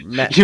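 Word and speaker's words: Mathieu: Matteo Amaro. – Matthew? You mean Matthew? – It's spelled French. It's spelled M-A. – Well, trust me, French Canadian, we know that's Matthew.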